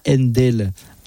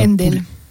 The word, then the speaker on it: Handel: De Handel à... Handel, pardon. – De quoi ? – Handel, ça se prononce. – C'est Handel, ok.